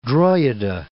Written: Drogheda

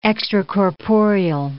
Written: extracorporeal

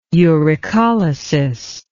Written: uricolysis

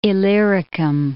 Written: Illyricum

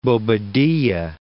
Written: Bobadilla

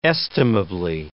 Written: estimably

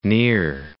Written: Near Islands

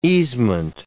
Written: easement

